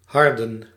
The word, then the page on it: durcir: /dyʁ.siʁ/; France